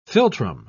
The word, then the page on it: philtrum: British English